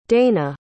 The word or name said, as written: daina